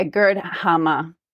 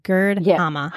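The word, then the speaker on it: Gerd Hamer: Yeah, Gerd Hammer. – Oh, Gerd Hammer.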